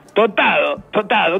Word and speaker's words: tostado: Si nosotros no pronunciamos la S, ¿por qué le decís le vamos a decir tostado al sándwich caliente, señor? No pronunciamos la S que viene antes de una oclusiva, de una consonante oclusiva, señor.